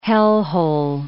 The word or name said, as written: hellhole